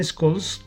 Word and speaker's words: Scholz: Eh, dijo el canciller alemán Scholz que Rusia lo que no quiere es entregar más gas y que cualquier, cualquier excusa es válida para ellos.